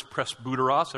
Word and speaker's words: presbyteros: The Greek word for elder is presbyteros.